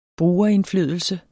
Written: brugerindflydelse

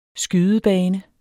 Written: skydebane

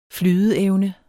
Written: flydeevne

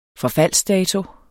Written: forfaldsdato

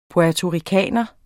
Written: puertoricaner

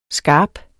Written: Skarpt

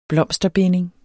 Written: blomsterbinding

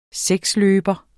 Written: seksløber

